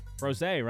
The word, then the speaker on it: frosé: And then we can crack open some frosés. Frosé, right? That's how you say it? – Frosé, yeah. You know,